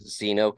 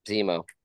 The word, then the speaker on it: Zemo: Zeno killed him in what's the show? I don't know if I said his name right. – Zemo.